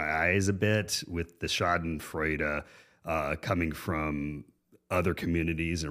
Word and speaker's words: Schadenfreude: Although I have been a little, I don't speak German, but there's the word, I think it's pronounced Schadenfreude.